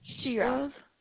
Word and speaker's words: Shira: Shira. – Shira. Can you spell that for me, please? – It's S-H-I-R-A.